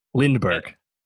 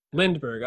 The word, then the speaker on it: Lindbergh: Lindenberg. You got it. Lindbergh. – Lindbergh. Oh, I'm sorry. I was like, am I saying it wrong? I was saying it wrong.